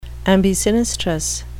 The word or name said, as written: ambisinistrous